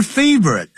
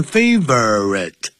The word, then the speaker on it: favorite: Her favorite 啊，这个是本来是念成 favorite 三个音节，可是念筷子是 favorite 变两个音节了。